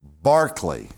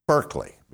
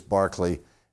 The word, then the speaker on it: Berkeley: And his name was George Barclay, is how it's pronounced. But if you look at it, you think it reads Berkeley because it's spelled like the university campus that we have in California that we pronounce Berkeley, but the pronunciation of his name is Barclay.